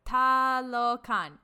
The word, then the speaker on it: Talokan: Yes. Talokan. Yeah, that's how you say it.